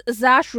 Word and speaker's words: Saoirse: Sauas Ronan, also, wie auch immer. – Ich glaube, es ist Saoirse Ronan und ich weiß, dass sie in jedem Interview ihren Namen aussprechen muss.